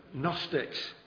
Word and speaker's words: Gnostic: In the first century, there were Gnostics, spelt with a G, Gnostic, it's a silent G.